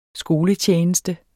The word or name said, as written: skoletjeneste